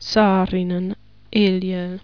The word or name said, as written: Saarinen Eliel